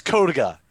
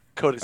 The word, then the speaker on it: Codiga: Is it Codiga? – It's Codiga. Codiga, yeah.